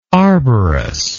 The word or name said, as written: arborous